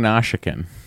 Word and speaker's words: Nausicaan: Why do you keep saying Nausicaan? – That's how you say it.